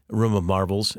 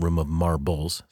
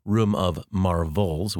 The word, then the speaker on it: Room of Marvels: It's not Room of Marbles. Some folks thought that was the title when I just say it. Room of Marvels with a V is a book that I wrote but didn't mean to write.